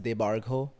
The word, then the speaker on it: Debarghya: My first name is pronounced Debargho, which is why it's very hard for everyone to say it. And it's spelled D-E-B-A-R-G-H-Y-A. And my last name is D-A-S.